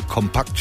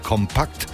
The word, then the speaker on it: Kompakt: Du musst kompakt packen, so wie ein Abwehrverbund bei einem Fuß- oder Handballspiel kompakt stehen muss. Aber bei einem Adjektiv und sei es noch so gut gemeint, kompakt kannst du nicht sein. Kompakt. Geben wir doch einfach der ersten Silbe den Betonungszuschlag und schon landen wir bei einem Dreiklang aus Plattenlabel und Vertrieb, Plattenladen und Künstlerbooking.